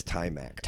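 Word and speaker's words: Taimak: Tamek, is that how you say his name? – I think it's Tymac.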